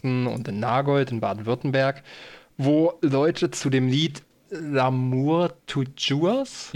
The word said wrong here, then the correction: L'amour toujours: Ich bin kein französisch sprechender Mensch, darum entschuldigt meine Betonung. – L'amour toujours. – L'amour toujours, danke.